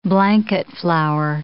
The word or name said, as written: blanket flower